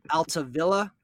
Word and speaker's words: Altavilla: San Diego gets Nola, right-handed pitcher Austin Adams, and Dan Altavilla, if I'm saying that, if I'm pronouncing Perfect. – That correctly. Beautiful.